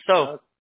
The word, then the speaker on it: Seo: G-S-E-O? – Yep, that's my last name, actually. S-E-O. – Okay. – It's pronounced so.